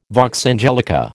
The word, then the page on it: vox angelica: American English